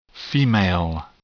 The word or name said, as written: female